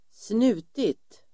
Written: snutit

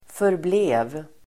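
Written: förblev

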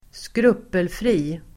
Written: skrupelfri

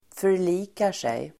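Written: förlikar sig

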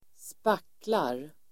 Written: spacklar

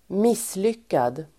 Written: misslyckad

